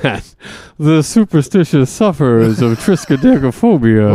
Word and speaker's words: triskaidekaphobia: Triskaidekaphobia. – That's right. Triskaidekaphobia. – So you've heard that. – Yeah, and am I pronouncing that correctly, yeah, Mr. Triskaidekaphobia. – Triskaidekaphobia. – Right.